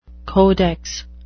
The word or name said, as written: codex